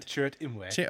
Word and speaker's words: Chirrut Imwe: Chirrut Imwe.